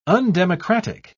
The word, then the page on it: undemocratic: British English